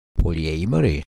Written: polyamori

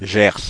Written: Gers

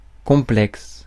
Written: complex

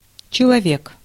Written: человек

